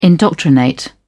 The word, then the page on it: indoctrinate: British English